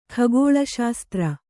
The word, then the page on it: ಖಗೋಳಶಾಸ್ತ್ರ: khagōḷa śastra